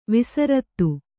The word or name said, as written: ವಿಸೃತ